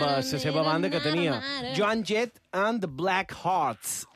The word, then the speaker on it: Joan: Cas, se nom Joan, però se pronuncia Joan, i és una lota, xeram, de...